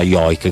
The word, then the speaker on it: joik: dello Yoik, forse Joik, ma quasi sicuramente si pronuncia Yoik, che è il canto tradizionale di questo popolo ed è molto particolare, un suono gutturale, ripetuto, insomma lo sentirete presto.